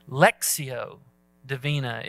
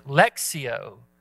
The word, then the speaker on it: Lectio Divina: Lectio Divina is the way I was told to pronounce this. Lectio is the proper pronunciation.